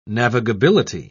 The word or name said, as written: navigability